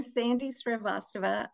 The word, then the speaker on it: Srivastava: I'm Sandy Srivastava.